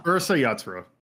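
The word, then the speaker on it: Urusei Yatsura: how do you say it, Urusaya Kawatsa? – You're talking about the show that it came from? – Yeah. – Ursa Yatsura. – Ursa Yatsura, yeah. Aliens.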